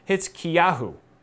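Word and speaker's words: Hezekiahu (חזקיהו): But that's not how you say it in Hebrew. How you say it in Hebrew is Hezekiahu.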